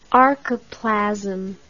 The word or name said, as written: archoplasm